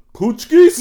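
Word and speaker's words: pączki: Is that how you say it? Pushkies? – Pushkies.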